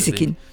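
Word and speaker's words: Gieseking: Giesekin. Op zijn Frans uitgesproken.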